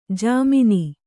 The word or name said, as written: ಝಮ್ಮನೆ